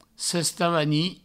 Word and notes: sestavanir: sestavani

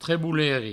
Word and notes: treboleri: trebouleri

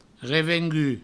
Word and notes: revengut: Provençal

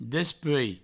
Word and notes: despuei: Provençal

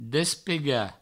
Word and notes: despegar: Provençal; despega

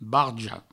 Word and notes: barja: barjo; Provençal

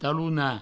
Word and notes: talonar: provençal; talouna

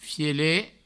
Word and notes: fielet: Provençal

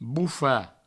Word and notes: bofar: boufa

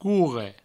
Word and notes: corre: courre

